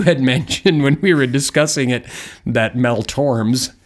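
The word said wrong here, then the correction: Tormé: Okay, so I said his last name wrong. Shoot me. – I'm sorry. I just had to bring that up. Mel Torme has a song called Bernie's Tune.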